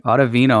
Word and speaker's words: Ottavino: Adam Ottavino, did I say that right? – Ottavino, I believe it is, yeah.